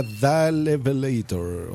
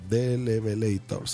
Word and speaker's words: The Levelator: Dalevelator o Delevelator. Se escribe T-H-E espacio Levelator con V.